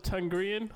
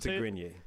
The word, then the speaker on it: Tigrinya: Shout out to Tigrinya. How do you pronounce it? – Tigrinya.